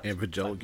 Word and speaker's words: Evangelion: Evangelion.